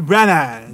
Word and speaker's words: Branagh: Kenneth Branagh, eu nunca sei pronunciar o sobrenome desse cara. Branagh. – É Kenneth Branagh. – Branagh. Ok.